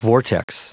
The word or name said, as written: vortex